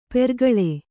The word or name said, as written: ಪೆರ್ಕಳ